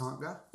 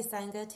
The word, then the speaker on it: Sangha: And it's pronounced Sangha? – Yeah. But okay. But I'm happy with, if people say Sangha too, it's either or. It's however you want it to be.